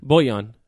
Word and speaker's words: Bojan: Bojan. It's not like Bullion.